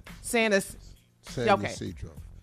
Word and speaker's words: San Isidro: Isidro? Is that it? – San Isidro.